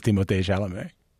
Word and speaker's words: Timothée Chalamet: By the way, everyone calls him Timothy Chalamet except for the Canadians who know his name is Timothée Chalamet.